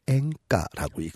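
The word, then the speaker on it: 앵가 (演歌): 일본의 대중가요는 연가라고 쓰고 한자어로 연가라고 쓰고 그들 말로 앵가라고 읽습니다.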